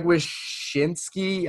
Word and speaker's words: Wyshynski: The other day – Greg Wyshynski, I'm definitely butchering that name, from ESPN tweeted out the locks, playoff locks from each conference. – You actually nailed it. Nice job.